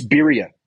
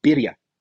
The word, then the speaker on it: birria: It's Birria. To pronounce it, you roll the R. Birria, Birria. There you go.